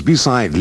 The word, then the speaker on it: beside: Virgo's beside Leo. In these dialogues, remember to pronounce the first E of below, beneath, beside, and between with the sound of the I in sit.